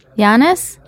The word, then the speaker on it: Yannis: Yannis? – Yeah.